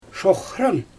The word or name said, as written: ʃoxran